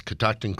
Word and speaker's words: Catoctin: So speaking of things with alcohol in them, Scott Harris is in with us, Catoctin Creek Distillery. – Yep. After five times being on the show, you've said it right this time.